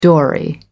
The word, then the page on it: dory: American English